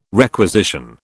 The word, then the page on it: requisition: British English